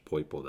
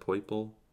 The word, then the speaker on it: Poipole: Remember I have that secret rare is that how you pronounce it, Poipole? – I think so. That's how I've always said it.